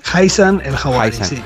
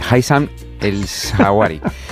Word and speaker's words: Haitham El Hawari: He dicho, he pronunciado bien tu nombre. – Más o menos, es Haitham El Hawari. – Haitham El Hawari, mejor el sobrenombre, el apellido que el nombre. Haitham El Hawari.